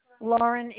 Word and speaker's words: Lauren: Lauren Ilardo. – Can you spell that first name for me? – L-A-U-R-E-N. Last name, E-L-A-R-D-O.